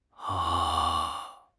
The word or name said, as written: a1